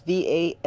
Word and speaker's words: Valerian: They got passion flower and they got valvoline. These things help. Could be saying it wrong. Valine. It's Valerian.